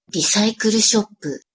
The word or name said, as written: risaikuru-shoppu